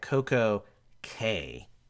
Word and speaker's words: Coco Cay: They don't pronounce it key like the Bahamans. – Makes zero sense. – Because in Bahama, C A Y is key. Um, Royal doesn't. They just wanted care. it to rhyme with.